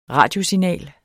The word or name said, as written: radiosignal